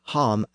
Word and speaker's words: ham: But here, the word home is spelled H-A-M. That reflects the northern pronunciation as ham. In the south, it would have been hom, spelled H-O-M.